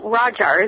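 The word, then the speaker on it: Ghajar: Well, Rajar is how it's pronounced in the local lingo.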